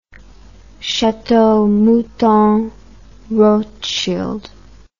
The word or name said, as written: chateau mouton rothschild